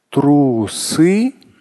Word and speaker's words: трусы: И я понимаю, что подчеркну слово трусы, не трусы, ну, это может близко одно к другому, но не важно. Трусы на первый слог ударения.